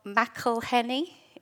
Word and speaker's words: McElhenney: is a guy called Rob McElhenney, is how you pronounce it.